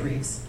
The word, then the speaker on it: Rives: It looks like Rives, R-I-V-E-S. But you say it Reeves. – Oh, I see. – Like George Reeves and R-I-V-E-S, but it's pronounced Reeves, like all the great Supermans and Herculeses.